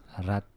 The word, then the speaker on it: Rat: À l'italienne, c'est-à-dire on prononce très fort les consonantes, donc ouais. Fabrizio Rat. – Rat.